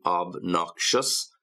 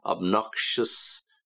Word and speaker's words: obnoxious: You got the same sound at the end, shus, obnoxious, pretentious.